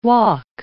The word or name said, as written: wok